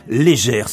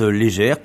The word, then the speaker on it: Léger: Et puis, il y aura aussi cet artiste d'origine canadienne, mais qui habite en Savoie, Marc-André Légère, ça s'écrit Léger mais ça se prononce Légère, qui a aussi remporté le trophée des Radio Blues au dernier Tremplin Blues de Mantes-la-Jolie au festival de Blues sur Seine.